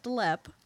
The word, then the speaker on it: Leap: which is actually spelled leap, but it's pronounced Lepp.